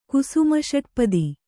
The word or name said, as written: ಕುಸುಮಷಟ್ಪದಿ